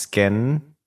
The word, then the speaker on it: SKKN: Yeah, who developed recently her brand SKKN, which I'm assuming is pronounced skin. – Yes. – Skin. – With two K's for Kim Kardashian.